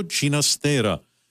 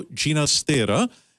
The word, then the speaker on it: Ginastera: As we tap into this ballet, Become a Concert Suite, by Alberto Ginastera, the Argentinian musician who preferred the old world Catalan pronunciation of the family name. So let's turn now to Alberto Ginastera and his latest Argentinian contribution for us,